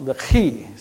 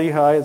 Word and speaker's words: Lehi: Now, my whole life since I was in eighth grade, I've been saying Lehi. Then I made the mistake of looking it up and finding that that H in Lehi is like the H in Chesed, and it should be pronounced Lehi. So you go ahead and call it Lehi. It's okay. But now I have to try to pronounce it the Hebrew way.